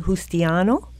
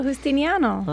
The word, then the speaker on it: Justiniano: Well, we welcome to the studio Irene Justiniano. Did I say that correctly? – Very close. Just missed the end that everybody missed. – Justiniano? – There you go, Justiniano.